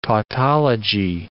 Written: tautology

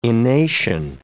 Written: enation

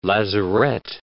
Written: lazarette

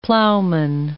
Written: plowman

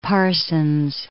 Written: Parsons

